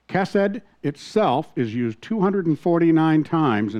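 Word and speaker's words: chesed (חסד): Today, we're looking at the ancient Hebrew word chesed, or hesed, pronounced chesed, it's a masculine noun, by the way.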